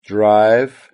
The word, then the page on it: drive: British English